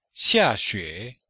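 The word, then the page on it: xiaxue: xia4xue3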